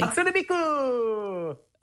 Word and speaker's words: Hatsune Miku: Hatsune Miku!